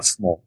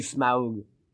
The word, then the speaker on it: Smaug: Smaug, who's covering like a giant Smaug. Smaug. – Oh, Smaug. – Yeah. – Not Smaug. – It's actually Smaug.